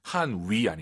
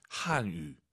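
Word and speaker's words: 汉语: 한위 아니고 입술 동그랗게 말아보세요. 동그랗게. 유, 한유. 한유.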